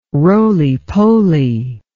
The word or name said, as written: roly poly